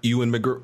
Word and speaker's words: Ewan: Shout out to, I always butcher his name. I always say, hear people say his name different. Ewan McGregor. – Ewan. It's Ewan.